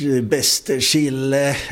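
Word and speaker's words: Bästekille: Hur säger ni själva som bor och uppväxt här? – Bäste Chile. – Ni säger bäste Chile?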